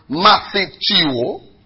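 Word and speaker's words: Matheteo (μαθητεύω): Matheteo. M-A-T-H, where you have Max. M-A-T-H-E. M-A-T-H-E. T-E-U-O.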